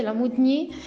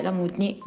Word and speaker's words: Lamoudni: Fabio, l'hai pronunciato benissimo, anzi hai dato con l'accento sulla I al mio cognome, è un tocco francese, Lamudni, in molti lo pronunciano così ed è bello, altrimenti semplicemente Lamudni.